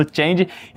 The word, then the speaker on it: Change: criador do conceito de competência baseado no Change. Acertei na pronúncia? – Isso. – Maravilha, no Change e do plano de ensino da disciplina de gestão emocional nas organizações.